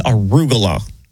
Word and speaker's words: arugula: No, it's actually, it's arugula. – No, it's a Googler. – It's the way I said it. And it just slipped out. But the correct pronunciation is arugula.